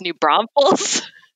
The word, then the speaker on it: New Braunfels: Well, you lived in New Brunsville. – Okay. You said it wrong. It's New Braunfels.